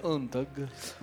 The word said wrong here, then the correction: Unstrung: Unstrung. Pinocchio Unstrung.